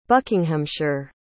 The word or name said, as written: buckinghamshire